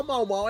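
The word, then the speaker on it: Maomao: Você chamar a personagem com a pronúncia correta, porque a porra da dublagem brasileira, pra não fazer parecer ser malvada, mal, chama ela de mal.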